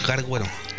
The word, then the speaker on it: garguero: Acción de estirar y recoger el guarguero. Gua no. Acción. corte, corte, corte. garguero. – Garguero.